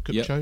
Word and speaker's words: Kupcho: Augusta National Women's Amateur Champion Jennifer Kupcho, am I saying that right? Kupcho, yep.